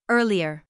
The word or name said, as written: earlier